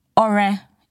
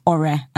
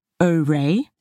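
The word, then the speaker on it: Ọrẹ: My full name is Ore Oluwa, and there's a dot under the O and a dot under the E to show that it is Ore and not Ore. And there's an accent on the U and the A to show that it goes Ore Oluwa, it goes up and down.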